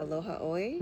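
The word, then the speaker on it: Aloha Oi (Aloha ʻOe): Every time I listen to Aloha Oi, is that how you pronounce yes. – it? – Yes.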